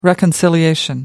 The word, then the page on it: reconciliation: American English